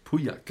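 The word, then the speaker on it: Pauillac: Äh, Puyak. Das L spricht man nicht, oder die Doppel-L spricht man nicht.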